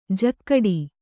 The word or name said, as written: ಜಕ್ಕಡಿ